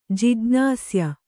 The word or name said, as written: ಜಿಜ್ಞಾಸ್ಯ